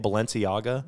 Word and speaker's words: Balenciaga: Balenciaga? – Yeah, very good.